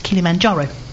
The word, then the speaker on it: Kilimanjaro: He was here to talk about his new adventure and fundraiser for SNAP, climbing Mount Kilimanjaro.